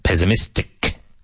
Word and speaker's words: pessimistic: Pessimistic. Don't be so pessimistic. And even though it's spelled with two S's, it sounds like a Z.